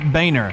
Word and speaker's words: Boehner: It's spelled Boner! Do you realize that? Biscuits! – Yeah, I do. I think the whole world does, really, Ollie, but... He pronounces it Boehner.